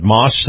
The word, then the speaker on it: Maus: This portion of the program brought to you by our very good friends at Moss, that's spelled M-A-U-S, Moss Family Automotive,